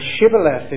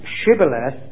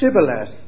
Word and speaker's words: Shibboleth: But at any rate, the Ephraimites couldn't pronounce Shibboleth. When they tried to, it came out as Sibboleth, which apparently means a burden.